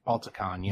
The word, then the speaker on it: Balticon: When you're out in, like at BaltCon, it's called, right? – Balticon, yeah. – Balticon, there we go. I knew I was saying it wrong.